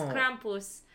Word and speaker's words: Krampus: Because that's the way it's supposed to be said. Oh. It's Krampus. – Okay. – Because it's German, so it's not Krampus.